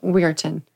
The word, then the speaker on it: Weirton: Weirton.